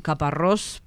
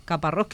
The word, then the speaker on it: Caparrós: El director técnico de la selección, el español Joaquín Caparrós, porque se acentúa al final, ¿verdad? Como los armenios en la última sílaba. Caparrós, que le ha dado mucha satisfacción a la selección Armenia.